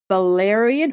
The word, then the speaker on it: valerian: Valerian.